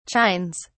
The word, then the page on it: chines: British English